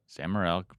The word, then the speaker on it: Sam Morril: You do one with Sam Morril. – Yep. – Did I say his name right? – Yep. – Sam Morril, funny comic.